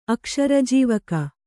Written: ಅಕ್ಷರಜೀವಕ

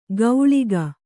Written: ಕೌಳಿಕ

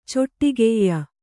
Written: ಚಟ್ಟಗೆಯಿ

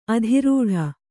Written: ಅದರಟ್ಟು

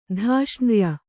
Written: ಧಾಷ್ಣ್ರ್ಯ

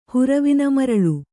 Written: ಹುರವಿನಮರಳು

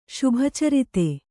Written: ಶುಭಚರಿತೆ

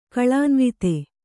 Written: ಕಳಾನ್ವಿತ

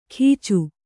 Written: ಗೀಚು

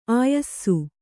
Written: ಆಯಸ

